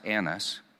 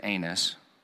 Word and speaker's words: Annas: First, they led him to Annas, pronounced Annas, but it is fun to say anus.